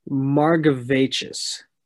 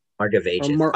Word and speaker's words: Margevicius: So Baseball Reference has the phonetic spelling. Margevicius. – Margevicius. – Margevicius? Because they're like putting the emphasis on the Gav part, so.